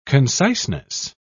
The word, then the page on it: conciseness: British English